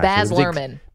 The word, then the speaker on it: Luhrmann: Baz Luhrmann. – Baz Luhrmann.